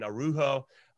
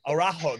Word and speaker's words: Araujo: They're playing with 2 21-year-old center backs, Mingueza and Araujo. I can't speak. – Araujo.